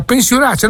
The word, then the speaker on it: pensionaccio: Allora, pensionaccio, no, dai la pronuncia esatta, prego, prego, dai.